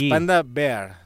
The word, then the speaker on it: Panda Bear: és Panda Bear, no Bear.